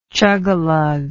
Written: chugalugger

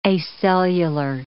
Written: acellular

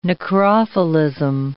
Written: necrophilism

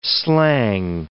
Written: slang

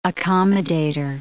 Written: accommodator

